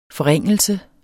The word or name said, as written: forringelse